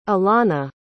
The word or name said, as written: alanna